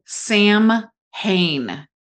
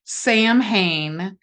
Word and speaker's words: Samhain: Listeners out there, when you see the word Sam Hain, it literally is pronounced Samhain.